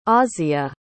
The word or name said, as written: asya